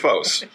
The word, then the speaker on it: FOS: P-H-O with the long O. – Long O. FOS is the Greek word for light.